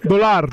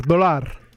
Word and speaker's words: dólar: dólar, dólar.